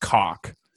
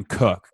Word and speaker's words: Koch: Like also in the NFL, there's this guy, his last name is spelled K-O-C-H, and that's come from a German background. Okay. And that in German is pronounced cock. But they call him Sam Cook because they don't want to say cock on TV.